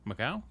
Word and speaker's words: Macau: Taiwan, Hong Kong, Macau. That's not how you pronounce it, is it? – Macau? Yeah, that is.